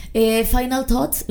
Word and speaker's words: Final thoughts: Final thoughts, ¿lo dije bien, Talavera? – Sí, ajá, sí.